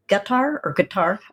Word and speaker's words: Qatar: So it's in Arabic, it's Qatar, but people say Qatar, whichever you'd like. I usually say Qatar. – Yeah, I had a CIA professor retired, and he pronounced it gutter.